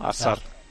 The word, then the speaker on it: Hazard: en Bélgica por lo menos le llaman Hazard, con la S así como un poco muy suave, ¿no? Hazard. Lo pronuncian en Bélgica, sí, porque le he preguntado.